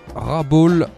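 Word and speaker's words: Rabaul: Rabol, c'est comme ça que ça se prononce, on va le dire comme ça.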